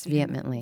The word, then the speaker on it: vehemently: And, of course, there's a quote that I always go back to when people vehemently, did I say that right? – Yes, vehemently.